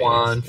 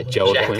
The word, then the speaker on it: Joaquin: Juan Phoenix. – Joaquin.